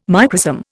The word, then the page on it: microsome: Indian